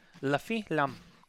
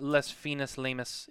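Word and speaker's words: Les Fines Lames: La fin lam. That's how you say it. La fin lam. All right. Not les finis lamus. It's la fin lam.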